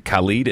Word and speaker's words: Khalid: And you pronounced one of them wrong. – Khalid? – Yeah. – Thank you. – Is it Khalid and DJ Khaled? – Yes.